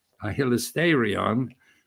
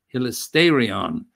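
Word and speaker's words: hilasterion (ἱλαστήριον): There are only two places where this Greek word is used, hilasterion. You would spell it in English H-I-L-A-S-T-E, but it's an eta, pronounced A-R-I-O-N, hilasterion.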